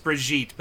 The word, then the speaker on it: Brigitte: Bridget, I want to say, or whatever her name is, like Brigitte. – I think it's pronounced Brigitte, but Brigitte, yeah.